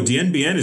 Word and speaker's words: Dien Bien: We're talking Vietnam's, and I might butcher this, Dien Bien province. – Oh, Dien Bien is very famous.